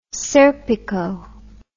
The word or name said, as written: serpico